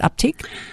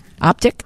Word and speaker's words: Optiq: Is that how you pronounce it? Optiq? – Yeah. – Optiq.